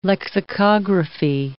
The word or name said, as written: lexicography